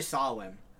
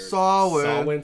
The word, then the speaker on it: Samhain: So it's actually Samhain, which I thought was really weird. – Samhain. – That's how it's pronounced? – That's how it's pronounced, yeah.